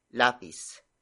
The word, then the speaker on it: lapis: Nang is the antifocus marker performing its role as an indicator for direct object, which is lapis.